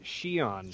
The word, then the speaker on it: Xian: We also have the Twi'lek, the purple Twi'lek, named Yeon, I think is how you pronounce it, although it's like spelt X-I-A-N? – They pronounced it Xion.